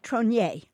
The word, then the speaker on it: Tronier: And I see that Jenny Tronier? Tronier. She does the French pronunciation.